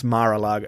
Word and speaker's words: Mar-a-Lago: I never know how to pronounce Mar-a-Lago. Is it like Mar-a-Lago? – I thought you did it quite well.